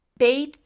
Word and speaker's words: Být: Long Y and long E, which are both pronounced as E, turned into A, and long U into O. So, for example, the good demonstrative sentence would be, according to Aneta, Být dobrý strýc, to be a good uncle, would be in Bohemian Bejt dobrej strejc.